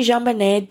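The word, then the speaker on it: JonBenét: Her mother used the soft French J when she called her by the name, and her father used the hard J. As a baby, Jean Benet didn't cry much.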